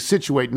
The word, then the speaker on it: Scituate: From Frank Kelly in Scituate, Massachusetts, and great job pronouncing Scituate.